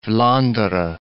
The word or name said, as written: Vlaanderen